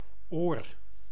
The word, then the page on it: oor: [oːr]; /o:r/